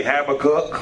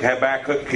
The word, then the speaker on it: Habakkuk: Some people say Habakkuk. Depends on how you want to pronounce it. I think Habakkuk is pretty good.